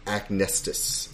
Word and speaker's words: Acnestis: So I believe this is pronounced Acnestus. – Do you have Acnestus? A-C-N-E-S-T-I-S.